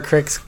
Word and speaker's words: Cryx: I said the name wrong, didn't I? – Yeah. I've been saying Crix this whole time. – I'll have one of whatever Crix got.